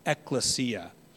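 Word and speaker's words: Ekklesia (ἐκκλησία): Excuse me, I'm saying it wrong. Ekklesia. In the Greek it's, say it with me guys, help me out. Ekklesia. Ekklesia. Okay, not ecclesia, it's ekklesia. Just like it's not exousia, it's exousia.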